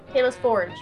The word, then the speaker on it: Tayliss Forge: Tayliss Forge.